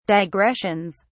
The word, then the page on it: digressions: British English